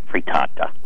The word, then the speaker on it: frittata: Is it frittata? – Frittata.